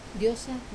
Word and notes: osa: wamesa

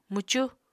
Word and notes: mwcu: Apatani

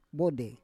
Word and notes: vode: Roon

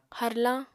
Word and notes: harlañ: apatani

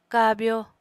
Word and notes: kabyo: apatani

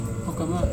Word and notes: hokama: Serewen

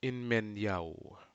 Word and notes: inmanyau: Aneityum

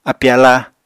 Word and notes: api-aláa: Apatani

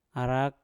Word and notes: arak: mpur

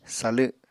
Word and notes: salw: apatani

